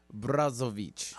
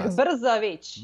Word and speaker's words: Brzović: Brzović. – Oh, I love it. – But I think it's with more of an O. – Oh, it's like Brzović.